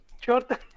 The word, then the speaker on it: Jota: Jota, am I saying it right? – Yeah.